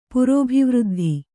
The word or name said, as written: ಪುರೋಭಿವೃದ್ಧಿ